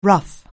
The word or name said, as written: rough